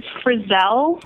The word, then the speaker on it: Frizzell: My last name is Frizzell. That's F-R-I-Z-Z-E-L-L. – Frizzell. – Accent on the second syllable.